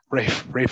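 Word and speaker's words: Ralph: I was so focused on pronouncing Rafe correctly that I said Fiennes. So Rafe Fiennes.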